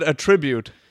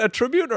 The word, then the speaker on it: attribute: Is it attribute or attribute? It's spelled the same. – Yeah, I think it would be attribute, wouldn't it? – Yeah, I think. Attribute is a noun. Attribute is the verb.